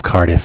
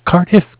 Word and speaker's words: Cardiff: It's spelled C-A-R as in car, D as in dog, I as in island, F-F as in Frank, like Cardiff, Wales, if you're familiar with England and all.